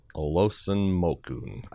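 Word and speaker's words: Olusanmokun: Olsonmokun, I think is how you pronounce the name. Yeah, no. Yes, you're right.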